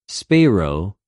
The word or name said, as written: σπειρω